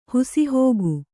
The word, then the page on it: ಹಾಸುಹೊಕ್ಕು: Kannada